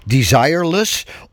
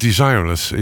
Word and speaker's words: Desireless: Waarvan ik me afvraag of het niet moet uitspreken als het Engelse desireless. Of meer het Frans klinkende Desiree Less. – Ik dacht desireless was het de bedoeling. Maar op zijn Engels.